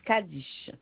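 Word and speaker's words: Cadiche: Alors, Cheguin, alors, C-H-E, oui. – Alors, on recommence. – Alors, Cheguin, Cheguin, Cheguin. – Non. Seguin Kadish. Alors, Seguin, S-E-G-U-I-N, trait d'union. – Oui. – Alors, Kadish s'écrit C-A-D-I-S-I. C-H-E. C-A-D-I-C-H-E. Kadish. – D'accord, madame. Le prénom, s'il vous plaît? – Le prénom, c'est Léziane. Alors, L-E-A.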